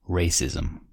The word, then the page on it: racism: American English